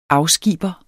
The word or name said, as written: afskiber